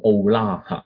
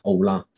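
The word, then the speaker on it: 奥 拉 (עֹלָה): 嗱，燔祭啦呢一个字其实喺原文呢系读作奥拉，奥拉。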